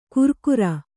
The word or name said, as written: ಕುರ್ಕುರ